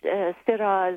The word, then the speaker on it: Syrah: Syrah. – Exactly, yeah. Exactly. The same grape, same clone. Of course, over the years, they've taken on the influences of the local environment, but and so the French Syrah is very popular in the Rhone Valley.